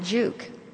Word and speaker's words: Giauque: It was spelled G-I-A-U-Q-U-E, and we pronounced it Juke.